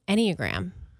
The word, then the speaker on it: Enneagram: Enneagram.